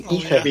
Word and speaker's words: Aethyr: And can I actually Ether again, until I'd heard Ryan narrating the story here, it hadn't actually hit me that his name is a pun on the Ether, because it's spelled A-E-T-H-Y-R, isn't it? Ether, yeah.